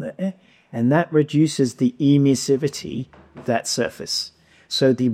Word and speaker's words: emissivity: Can you please explain what, and I don't even know how to say this, that E in low E, emissivity.